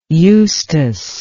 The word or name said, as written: eustace